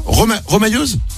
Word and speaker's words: remailleuse: On dit, ça se prononce pas comme ça? Remailleuse? – Remailleuse, oui. – Ah, remailleuse, pardon.